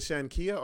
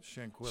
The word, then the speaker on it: Shanquella: Is it Shankia or Shanquilla? – Shanquilla. – Shanquilla Robinson. – Yep.